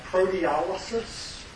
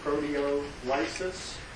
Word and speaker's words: proteolysis: Proteolysis, proteolysis, to say it slowly, proteolysis, or proteolysis is how it's often pronounced.